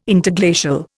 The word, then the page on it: Interglacial: Indian